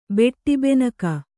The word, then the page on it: ಬೆಟ್ಟಿಬೆನಕ: beṭṭi benaka